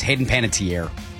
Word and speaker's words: Panettiere: Am I pronouncing her name right? Hayden Panettiere? – No, it's Hayden Panettiere. – Tier. Dang it.